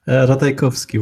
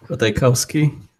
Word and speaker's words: Ratajkowski: Ratajkowski would be the Polish pronunciation. Ratajkowski is what Americans or Brits would say, I guess.